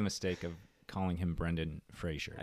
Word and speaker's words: Fraser: it is clearly F R A S E R and yeah, pronounced yeah. Fraser. Right. Not not Frasier Crane from from the great sitcom Frasier.